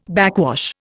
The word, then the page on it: backwash: Indian